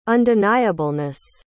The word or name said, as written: undeniableness